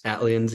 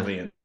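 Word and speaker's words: ATLiens: And now I know it's supposed to be like Atlanta, ATL, but is it pronounced Atlians? – Atlians, yeah. – Atlians. Okay, that's what I thought.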